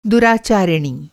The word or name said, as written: दुराचारिणी